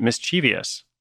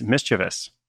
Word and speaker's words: mischievous: But for me, for years, I said mischievous. Like, that behavior is mischievous. Well, guess what? I was adding some extra vowels, at least one extra vowel to that word. The correct pronunciation is mischievous, which I'd actually heard before.